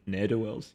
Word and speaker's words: ne'er-do-wells: How do you say that? Mm-hmm. Is it ne'er-do-wells? Yeah, ne'er-do-wells. – Yeah, exactly.